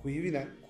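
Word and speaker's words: Cuiviénen: Cüvinen, cüvi de şey demek ya da cüvi doğru değil tabii bütün C'ler K okunur ben ağız alışkanlığı öyle diyorum. Kuyvinen, kuyviye uyanış demek uyanış gölü demek cüvinen.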